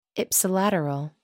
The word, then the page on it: ipsilateral: American English